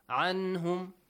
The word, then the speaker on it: عَنْهُمْ: Why here Nun is not hidden? عَنْهُمْ. I pronounce it clearly. – Because after Nun, it's the letter Ha. – After Nun, we have the letter Ha. The letter Ha is from throat letters. Yes. The rule Idhar must be applied.